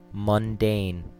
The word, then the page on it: mundane: American English